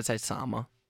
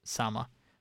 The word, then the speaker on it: Sama: Sama? I want to say Selma, but that's not how it's spelled. – I'd say Sama. – Sama.